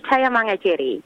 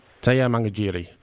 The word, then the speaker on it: Tayamangajirri: Sure, it's Tayamangajirri. – Tayamangajirri.